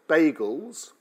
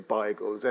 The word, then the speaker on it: beigels: I had no idea that bagels, or beigels, as my father liked to call them, and that actually is the proper pronunciation of the word, is beigels.